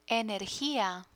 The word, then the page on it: energia: Spanish